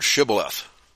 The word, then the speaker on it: shibboleth: Okay, Swamp Fox wants to know if you can pronounce the word shibboleth.